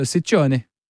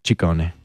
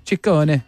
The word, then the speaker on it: Ciccone: Well, in Italian, it works the other way. So the double C's are hard C's and the singular C's are soft C's. – So Ciccione. – Ciccone. – Ciccone. Madonna Ciccone. God, why'd she drop that? That's good. It's Ciccone.